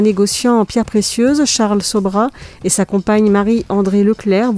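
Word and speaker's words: Sobhraj: Alors, je vais vous parler d'une série qui s'appelle Le Serpent, qui est l'histoire de l'escroc Charles, je sais pas comment ça se prononce, je veux dire Sobra, il y a un J à la fin, mais voilà, on l'appelait Sobra.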